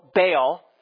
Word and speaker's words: Baal: But think of the gods Baal and Asherim. So Baal, that's how we pronounce it, but in Hebrew it's Baal.